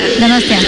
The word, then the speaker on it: Donostia: How do I say it? Donostia. – Donostia.